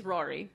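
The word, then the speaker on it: Ruairí: But it is spelled R-U-A-I-R-I. And I was like, what? But it's Rory.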